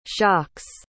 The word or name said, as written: shocks